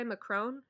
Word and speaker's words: Macron: Macron, is that how you pronounce it? – Yeah.